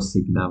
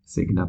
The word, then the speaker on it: Signa: jako píše se to Signa vlastně, přesně jak se vyslovuje, cizina eh, jako německy, Signa prostě,